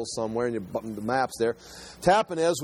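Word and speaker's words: Tahpanhes: But I was going to say Tahpenes, but it's Tahpenes.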